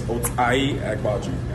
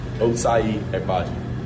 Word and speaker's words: Ochai Agbaji: Real way, like the real way you say my name is Ochai Agbaji. – Ochai Agbaji.